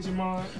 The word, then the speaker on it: Jemond: And Big Jemond? – My name is spelled JEMOND, and then the letter G. Jemond G. It's almost like that movie Jumanji.